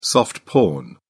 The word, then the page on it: soft porn: British English